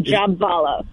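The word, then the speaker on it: Jhabvala: Is the B silent? – It is not silent. It is Jabvala.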